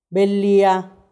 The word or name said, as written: belhía